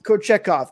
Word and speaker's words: Kochetkov: I'm going to butcher his name, but it's Kochetrov. – Peter Kochetkov. – Kochetkov, thank you.